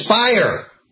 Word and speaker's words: Speier: Please throw to have Congresswoman Jackie Speier, rhymes with liar, running around saying, oh, the rumors everywhere, Trump is going to fire Mueller.